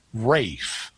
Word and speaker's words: Ralph: I'm not sure how he pronounced his first name either, because the name Ralph, R-A-L-P-H, I know this one. – Is sometimes pronounced Rafe. – Yeah. – That's like the L drops out.